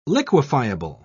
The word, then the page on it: liquefiable: British English